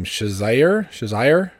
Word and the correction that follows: Cheshire: No, Cheshire. – Cheshire? – Cheshire, yes. – Yes, think of the Cheshire cat from, what's it called?